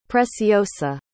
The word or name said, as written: preciosa